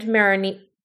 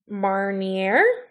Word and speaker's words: Marnier: It's made of Grand Marinière. Marnier? Sorry.